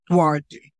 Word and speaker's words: Duarte: It's like D-W-A, Duarte. D-U-A-R-T-E, but it sounds like Duarte.